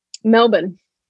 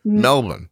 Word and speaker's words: Melbourne: Melbourne. – You just swallowed like five of the letters. Just so you know, you went Melbourne.